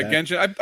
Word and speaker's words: Genshin: Yeah, Genshin.